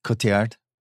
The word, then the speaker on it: Cotillard: Cotillard.